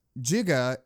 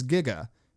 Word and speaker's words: giga: I learned that giga is an accepted alternate pronunciation of the prefix giga, meaning 1,000.